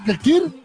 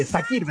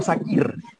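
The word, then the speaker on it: Çakır: o sea, eh, eh, Sakir vendría siendo como una pronunciación más, eh, más correcta. Kakir. Claro. es como esa, C con el con la coma debajo se pronuncia más como, como, como que fuera como una S.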